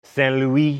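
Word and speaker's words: Saint-Louis: Oh, Stan, don't make me say it right. Fine. Saint Louis, and at a town called Goree, both in Senegal.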